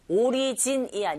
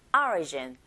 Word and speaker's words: origin: 나머지가 모두 다 내용어인데, origin, 오리진이 아니고 origin. 자, 앞에 철자 O의 강세가 딱 갔어요. 이런 경우에 O 소리보다는 I에 가깝게 발음이 들린다는 거 이제는 아시죠?